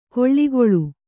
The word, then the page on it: ಕೊಳ್ಳಿಗೊಳು: koḷḷigoḷu